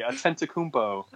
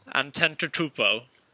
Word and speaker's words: Antetokounmpo: say it with me, Antetokounmpo. – Antetokounmpo. – That's better. That's getting better. It's getting there.